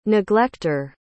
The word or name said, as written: neglecter